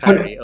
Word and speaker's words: Kyrie: I think it's Kylie, yes. Kyrie, sorry. – Oh, it's pronounced Kyrie, okay. – How do you spell Kyrie? – K-Y-R-I-E.